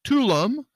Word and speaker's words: Tulum: Tulum, or Cancun, or any of the quote Mexican Riviera.